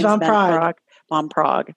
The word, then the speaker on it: Van Praagh: Wayne Dyer, Louise Hay, Christian Northrup, Carolyn Mace, James Van Praagh, is that how you pronounce the name? – James Van Praagh, to name a few.